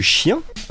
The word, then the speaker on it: Shien: Alors moi je voulais vous parler de Chien, artiste parisien. Alors ça s'écrit S-H-I-E-N, hein, à pas confondre avec l'animal.